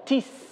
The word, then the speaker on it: tis: Otra palabra griega que se pronuncia tis, T-I-S, tis.